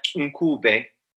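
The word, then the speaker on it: Ncube: And I always said the name as Nkube, but on my narrative therapy course, I got told that actually the correct pronunciation is something like Nkube. – Mm. Got a click in it.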